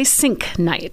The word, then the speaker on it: Sienknecht: We say Sienknecht.